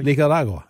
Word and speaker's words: Nicaragua: Or how do you say Nicaragua? – Nicaragua. – Yeah, there you go. There you go. – That's right, Nicaragua.